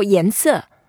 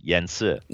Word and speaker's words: 颜色: The way to say color, we call 颜色. – Yes, 颜色.